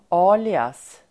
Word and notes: alias: ['a:lias]

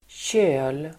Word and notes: köl: [tjö:l]

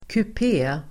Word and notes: kupé: [kup'e:]

